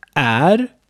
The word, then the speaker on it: är: Wir haben hier auch är, was Gegenwart von vara ist, also sein. Är wird aber oft wie e ausgesprochen, är oder e, je nach Dialekt oder je nach Satz.